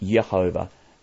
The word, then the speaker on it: Jehovah: The letter J, with that pronunciation, J, only came into the use in the English language around about 1500 or so. So no one before 1500 even had the pronunciation J. So it was always at least Yehovah.